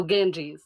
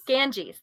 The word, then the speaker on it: Ganges: Oh, Ganges. – Ganges, thank you.